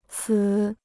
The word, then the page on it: 此: cǐ